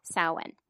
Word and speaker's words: Samhain: I get so confused by that word, the way it's spelled. – Samhain. – I can never remember because those letters don't line up.